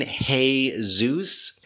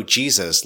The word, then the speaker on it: Jesus: Do you mean hey Zeus? And he typed back, No, Jesus, like rhymes with cheeses.